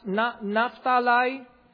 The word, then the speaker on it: Naphtali: Asher, Dan, Ephraim, Gad, Issachar, Manasseh, Naphtali, or sometimes pronounced Naphtali, Reuben, Simeon, and Zebulun.